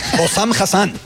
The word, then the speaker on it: Hossam Hassan: Hossam Hassan.